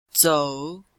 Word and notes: zou: zou3

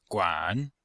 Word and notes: guan: guan3

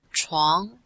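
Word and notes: chuang2: tone 2

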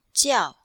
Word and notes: jiao4: female

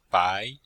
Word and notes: bai: bai2

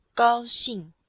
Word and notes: gao1xing4: female

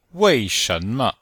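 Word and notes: wei4shen2me5: tone 4; tone 2; tone 5

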